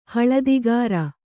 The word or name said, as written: ಹಳದಿಗಾರ